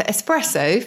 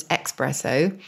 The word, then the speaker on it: espresso: So espresso, famously mispronounced as expresso, I think because people think it comes to you quickly or it comes out of the machine quickly.